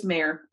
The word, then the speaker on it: Meagher: Her last name is spelled M-E-A-G-H-E-R, but I've looked it up a couple times and it's basically just Mayer, as in like, you know, M-E-Y-E-R in our stretch of the woods.